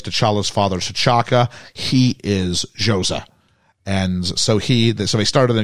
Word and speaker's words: Xhosa: Xhosa. X-H-O-S-A. I'm believing that would be pronounced Xhosa.